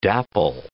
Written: dapple

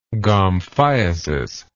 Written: gomphiasis

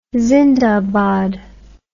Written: zindabad

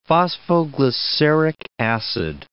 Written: phosphoglyceric acid

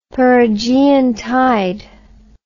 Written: perigean tide

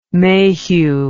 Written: Mayhew

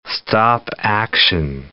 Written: stop-action

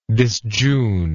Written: disjune

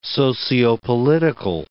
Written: sociopolitical

